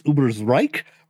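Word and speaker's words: Ubersreik: This isn't something part of our notes, but technically, the pronunciation is Ubersreich, but I'm not going to complain to you because it is canon that the Skaven have been known to pronounce it Ubersreich.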